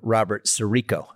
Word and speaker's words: Robert Sirico: Father Robert Sirico.